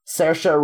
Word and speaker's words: Saoirse: it's uh, Cer- Cersei Ronan? Saoirse Ronan, uh, uh, who Louisa chose, so congratulations to Louisa.